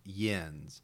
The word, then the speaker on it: Jens: It's spelled with a J, but his name is pronounced Jens.